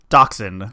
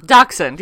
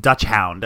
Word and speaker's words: dachshund: Dachshund. – Dachshund, yeah. – It's because it's spelt, I always want to say, like, Dutch hound.